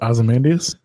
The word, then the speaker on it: Ozymandias: Wait, is that how you think that's pronounced? – What, Ozymandias? – Oh. Yeah, that is how it's pronounced.